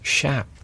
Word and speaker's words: chaps: Actually pronounced chaps. – Oh, are they fine? – I was told that by a cowboy in Montana.